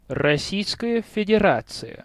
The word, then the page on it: Российская Федерация: Rossiyskaya Federatsiya